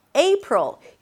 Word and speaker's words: April: April. The A is pronounced as A as in day. A. A. It's a diphthong, a changing vowel, so you want to hear it shifting from E to E.